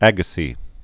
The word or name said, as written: Agassiz